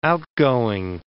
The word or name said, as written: outgoing